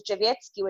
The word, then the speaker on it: Drzewiecki: Yes, J-U-S-T-Y-N-A. That's the first name. And then the second name is Drzewiecki, which is D-R-Z-E-W-I-E-C-K-I.